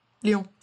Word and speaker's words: Lyon: Is the city pronounced Lyon? – Yeah, Lyon.